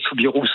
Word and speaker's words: Soubirous: Alors, chez les Bigourdans, on va dire Bernadette Soubirous, on va prononcer le S.